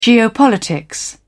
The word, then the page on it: geopolitics: British English